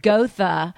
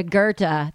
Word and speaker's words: Goethe: We also pronounce it Gotha as opposed to Goethe, the downtown street.